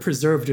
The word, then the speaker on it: Preserved: His name is actually, so the name form of the word preserved is pronounced with three syllables. Like preserved.